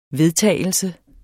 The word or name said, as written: VEDTAGELSEN